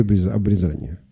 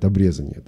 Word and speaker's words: обрезание: Значит, до сих пор для того, чтобы стать иудеем, нужно было мужчине пройти обрезание. Ну, или, как некоторые как говорят, обрезание. Да, в разных источниках по-разному стоит ударение.